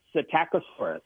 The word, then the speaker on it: Psittacosaurus: You know, I think you got it right on the second try. It's Psittacosaurus. – Psittacosaurus. – Psittacosaurus. It does sound good, doesn't it? – But I like Psittacosaurus.